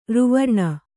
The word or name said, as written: ಋವರ್ಣ